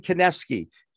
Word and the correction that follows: Kanefsky: Please tell me I pronounced those names correctly. it was very close. Great to be with you. It's Calcagni and Kanefsky.